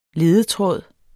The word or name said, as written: LEDES